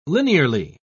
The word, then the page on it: linearly: British English